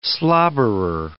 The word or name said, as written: slobberer